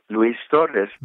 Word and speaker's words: Luis Torres: Well, I pronounced my name in Spanish. It was the name my mother gave me. Luis Torres.